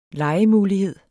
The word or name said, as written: legemulighed